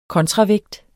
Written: kontravægt

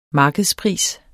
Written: markedspris

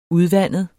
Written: udvandet

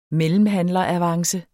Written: mellemhandleravance

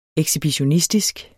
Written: ekshibitionistisk

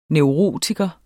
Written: neurotiker

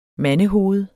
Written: mandehoved

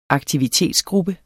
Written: aktivitetsgruppe

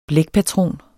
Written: blækpatron

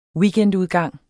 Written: weekendudgang

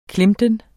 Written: klemten

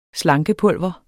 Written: slankepulver

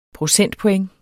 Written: procentpoint

